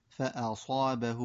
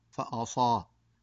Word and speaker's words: فأصابه: فكلمة مثلا فأصابه وابل، كثير من التلاميذ يخطأ في الهمزة المفتوحة فيقول فأصاه، وهذا خطأ ينبغي للمعلم أن يصححه للتلميذ إن وقع فيه.